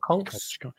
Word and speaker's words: conch: I've got two conchs or conches. Yeah, conchs. – I say conch, but it's conch, yeah. Is a correct pronunciation.